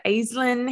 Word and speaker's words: Aislinn: We have Aislinn here.